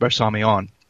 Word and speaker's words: Barsamian: I'm pronouncing his name correctly, by the way. I learned in Denver his name's pronounced Barsamian.